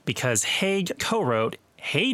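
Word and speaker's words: Hague: Four of their top tens on the Hot 100, including West End Girls, were produced by a gentleman named Stephen Haig. That's H-A-G-U-E. I hope I'm pronouncing his last name correctly. – That's how you say the Haig.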